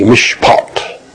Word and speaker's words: mishpat: All right, in the Greek we have mishpat, M-I-S-H-P-A-T. Pronounced like mish, M-I-S-H, plus pot, P-A-W-T, mishpat.